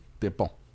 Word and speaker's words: Despentes: If you're an American, you say this lady's name, Virginie Despentes. Despentes. But I looked it up on YouTube, and it's Virgin de Pont. That's how you say it.